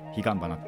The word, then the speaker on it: 曼珠沙華: じゅしゃげって書いてね、彼岸花って読むんですけど、まあ、なんだろう、秋のだからお彼岸についてのお話ですね。